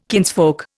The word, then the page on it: kinsfolk: Indian